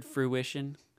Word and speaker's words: fruition: You ever thought about that word fruition? It's kind of a fun word. It rhymes with tuition.